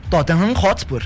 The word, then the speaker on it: Tottenham Hotspur: Tem o McTominay, tem o Andy Robertson, que era jogador do Liverpool, agora parece que tá de mudança aí pro Tottenham Hotspur. – Como é que é o nome? – O Andy Robertson. – Não, você falou Tottenham. – Tottenham Hotspur.